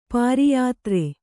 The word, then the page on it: ಪಾರಿಯಾತ್ರೆ: pāriyātre